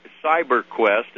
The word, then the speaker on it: CYBRQuest: What, CyberQuest, and it's spelled C-Y-B-R. There's no E in it. C-Y-B-R.